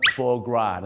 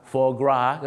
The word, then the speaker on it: foie gras: เสียงว่า foie gras นะฮะ foie ไม่ใช่ฝอยกราสนะครับ foie gras